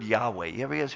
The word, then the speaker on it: Yahweh: And so the best of their biblical scholars say it's, we think it's provided, pronounced Yahweh.